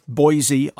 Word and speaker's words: Boise: It's Boise, Idaho. Not Boise, B-O-I-S-E. – No, I know Boise, Idaho. That I know.